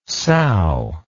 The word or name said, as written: sau